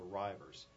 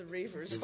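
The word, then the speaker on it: Reivers: He died shortly after this was published in 1962, called The Rivers. And it's essentially... – Oh, is that how you pronounce that? – Yeah. – Man, I've been pronouncing that The Reavers all my life.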